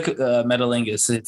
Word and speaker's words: Metalingus: They took away Metal Guinness. How do you pronounce that word? – Well, yeah, they took Metalingus. Metalingus.